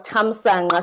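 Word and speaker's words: Thamsanqa: And so the Q is, my dad's name was Tamsanga. So the third syllable was NQA.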